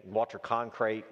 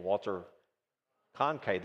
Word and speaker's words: Walter Cronkite: My dad always had a particular person that he, Walter Cronkite, I just butchered his name, Walter Cronkite, there it is,